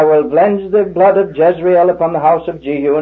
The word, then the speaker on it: Jezreel: And we should pronounce that Jezreel, because the L is a separate syllable and means Jehovah.